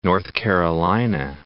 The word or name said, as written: North Carolina